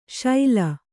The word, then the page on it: ಶೈಲ: kannada